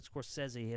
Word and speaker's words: Scorsese: The fact that I said Scorsese, as he knows as a film student, like, I'm pretentious alone because that is how you say his name.